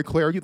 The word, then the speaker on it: Clardy: Follow me on Twitter at Troy Clardy. The last name is spelled C L A R D Y.